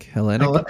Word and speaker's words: Hellenic: So, and then the Greek pronunciation in number 3 by Mr. Linnell, who claims to be one-eighth Hellenic Where's the emphasis on that? Hellenic? – Hellenic.